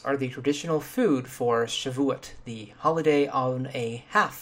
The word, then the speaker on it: Shavuot: Shavuot, or maybe Shavuot, which I originally pronounced a few weeks ago as the French might, as a uh, Chevois, is the ninth big holiday.